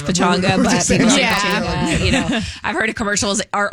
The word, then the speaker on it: Pechanga: Pechanga.